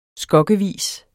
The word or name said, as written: skokkevis